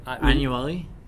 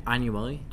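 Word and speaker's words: Ianuale: And then we have our good friend Corey Ianuel. – Close enough. – How would you say it? – Ianuelli. – Say it again. – Ianuelli. – Ianuelli. It's I-A-N-U-A-L-E for those of you who want to connect with him on LinkedIn.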